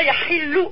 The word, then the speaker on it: yahilu: Voilà, yahilou. Alors justement, le U, il faut le prononcer où. Et tout le U à chaque fois, okay. – Il faut le prononcer où. – Où, ok, merci. Alors, yahilou. – Avec un H bien expiré.